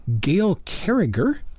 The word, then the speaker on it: Carriger: We have been listening to Gail Carriger with a hard G. That's C A R R I G E.R.,